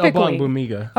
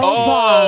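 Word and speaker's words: Ogbongbemiga: And to get the production that he got out of, again, guys like DeMarco Jackson and Amen Ogbog Bamiga. – I think it's Ogbong. OGB typically is the G is silent in the first part.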